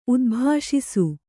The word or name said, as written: ಉದ್ಭಾಷಿಸು